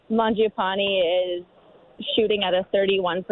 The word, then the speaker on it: Mangiapane: Okay, this is how to pronounce Andrew Mangiapane's name correctly as pronounced by my Italian producer.